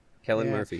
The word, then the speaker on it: Cillian: But like, they bring in the Scarecrow to replace him as like a male lead in this one, and Cillian, is it Cillian? I think that's how you say it. – I think it's right? – Kellen. – Cillian Murphy. – Kellen Murphy. – Is it? – Kellen Murphy, yeah.